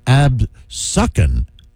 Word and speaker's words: Absecon: It seems like the first one is just Absecon, but the second one from Algis is obviously spelled or pronounced Absuckin.